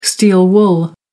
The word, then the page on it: steel wool: American English